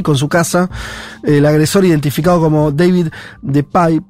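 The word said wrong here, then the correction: DePape: de Pape, se debe pronunciar, de 42, 42 años, irrumpió la vivienda al grito de ¿Dónde está Nancy?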